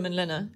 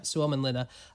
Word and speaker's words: Suomenlinna: The place in Finland, which is called Suomenlinna, is it? How would you say it? – Suomenlinna, yeah. – Suomenlinna.